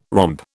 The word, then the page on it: romp: British English